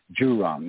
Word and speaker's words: Zhurong: He swears it's a J, a weird J, just like the J on Jezero. But it's a it's Zhurong, not a.